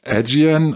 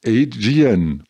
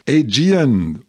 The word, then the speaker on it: Aegean: Also, das heißt nicht Aegean oder Aegean oder Aegean, das heißt Aegean. Tausend Dank, Jörg. Hauptsache, die Community denkt mit und spricht mit. Also, Aegean Star Alliance lockt mal wieder mit 20% Rabatt für Flüge nach Griechenland zwischen 1.5.